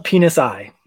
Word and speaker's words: Penisi: So how do you say the captain's name in that? Cause it's spelled penis. I, that's what it's spelled as. – Penisi.